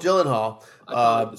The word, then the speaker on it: Gyllenhaal: Good question. Maggie Gyllenhaal. – Gyllenhaal. – Soft G.